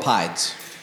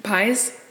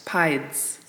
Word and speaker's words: pied: We call them pides. – Okay. So, pies? – Pides. – Pides. – Yeah. Like, okay. – Like a pied.